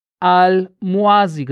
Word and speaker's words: Al-Mawasi: Die israelische Armee hat nie von Rafah gesprochen als Zufluchtsort, sondern immer Al-Muazi gesagt. Al-Mawasi schreibt man es.